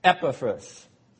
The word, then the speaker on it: Epaphras: Accent on the first syllable. Epaphras.